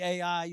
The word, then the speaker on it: Ai: and rise up and go to Ai. And some pronounce it I, okay? But I say Ai, you can say whatever you want when you're preaching.